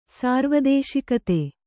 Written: ಸಾರ್ವದೇಶಿಕತೆ

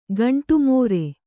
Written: ಗಂಡುಮೀರು

